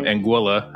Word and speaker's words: Anguilla: In the beginning, just go through kind of your pathway into medicine from Anguilla. I said that correctly, right? – Thank you for saying it correctly.